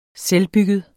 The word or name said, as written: selvbygget